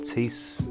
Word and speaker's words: Tiece: So that was Shadow People by Luke Strange featuring Tease. I think that's how you pronounce her name, T-I-E-C-E.